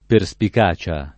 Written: perspicacia

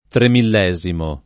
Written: tremillesimo

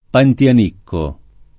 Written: Pantianicco

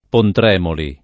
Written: Pontremoli

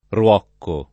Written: Ruocco

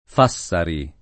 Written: Fassari

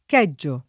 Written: chiedere

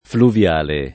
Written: fluviale

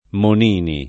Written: Monini

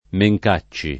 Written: Mencacci

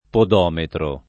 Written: podometro

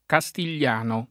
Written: castigliano

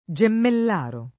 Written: Gemmellaro

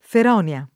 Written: Feronia

